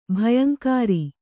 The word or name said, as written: ಭಯಂಕಾರಿ